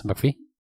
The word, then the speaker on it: McPhee: McPhee, se pronuncia McPhee.